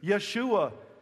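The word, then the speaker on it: Yeshua (ישוע): He's told that his name will be Jesus, Yeshua, it would have been pronounced, which means the Lord saves.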